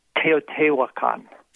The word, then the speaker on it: Teotihuacan: The Toltec pyramid ruins are outside of Mexico City, and it's called Teotihuacan. – Okay, Teotihuacan.